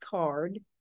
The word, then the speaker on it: CAURD: the acronym is C-A-U-R-D, pronounced CARD, stands for Conditional Adult Use Recreational Dispensary License.